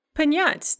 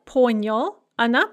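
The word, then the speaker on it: Panyat (понять): Panyat, to understand in the perfective, on ponyal, ana ponyala, ano ponyala, ani ponyali.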